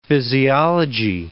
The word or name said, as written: physiology